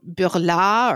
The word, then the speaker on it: Burlat: And then I didn't see this mentioned anywhere, but I've also in France had a type of cherry called Burla, or spelled like Burlat. – Okay. – And that was delicious, like a dark red cherry. Burlat.